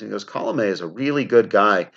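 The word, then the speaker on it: Colomé: But one of the things I can talk about that he told me was that Alex Colome, I got to get the pronunciation correct there. I believe it is Colome.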